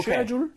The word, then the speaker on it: schedule: 첫 번째는 그냥 schedule처럼 발음하는 겁니다. 두 번 들려드릴게요. Schedule. Schedule. – Schedule. Schedule. – 자, 두 번째는요. share로 해서 schedule.